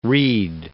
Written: Reid

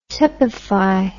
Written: tepefy

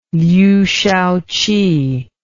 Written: Liu Shaoqi

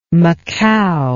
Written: macao

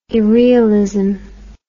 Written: irrealism

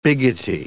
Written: biggety

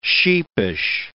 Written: sheepish

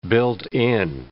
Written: built-in

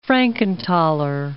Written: Frankenthaler

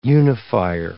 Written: unifier

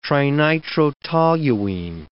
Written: trinitrotoluene

